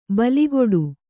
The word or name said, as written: ಬೆಲೆಗುಡು